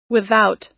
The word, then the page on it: without: American English